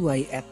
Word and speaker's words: Wyatt: bacanya apa sih Wyatt? Hmm? Wyatt. – Wyatt. Oh, Wyatt, Wyatt, W Y A T T.